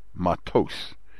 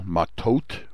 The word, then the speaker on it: Matot: And those with the Ashkenazic pronunciation of Hebrew called last week's sedra matos. We would say it in Israel, matot, with a T sound at the end, not an S sound.